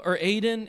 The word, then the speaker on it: Adino: There was Aiden is how you say his name, but it looks like Adino.